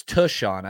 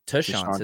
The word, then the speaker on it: Tashaun: Um, but it's Tushon. I think I've been saying it wrong. – Tushon is the pronunciation.